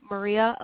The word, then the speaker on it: Maria: But you can find me at ME_, and it's spelled like Maria, so Maria Elizabeth, M-A-R-I-A, and then Elizabeth on Instagram.